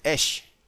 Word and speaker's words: esh: Porque fuego en hebreo se dice esh, no se dice or.